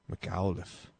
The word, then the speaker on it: McAuliffe: McAuliffe.